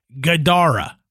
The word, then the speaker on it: Gadara: And this time, he said that it spoke a three-syllable word that sounded like Gadara, with the second syllable emphasized, mm-hmm.